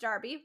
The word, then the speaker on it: derby: Which, again, spelled derby, pronounced darby.